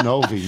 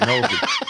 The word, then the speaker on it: Novi: And then he simply writes without pronunciation, it's Lake Orion, not Lake Orion. It's Novi, not Novi. It's Mackinac, not Mackinac.